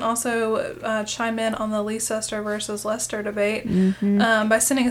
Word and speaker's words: Leicester: And by the way, it's Leicester. Bring it. – Leicester. Look at how it's spelled. You're missing a whole freaking syllable. – Exactly. Leicester.